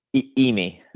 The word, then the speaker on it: Immi: How do you say Yimi? Is that it? Yimi ramen. – Yimi. – Yimi. Okay, everybody listening, Yimi Rami, IMMI.